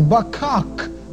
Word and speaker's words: baqaq: Verse 1, the word empty, the Hebrew word is bakak, B-A-Q-A-Q.